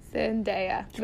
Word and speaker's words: Zendaya: Zendaya. Mary Jane. Zendaya.